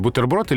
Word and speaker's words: бутерброд: Она тут узнала, что большой орфоэпический словарь русского языка, вышедший в 2012 году, рекомендует нам произносить слово «бутерброд» с безударным «и» после твердого «т» то есть бутерброд.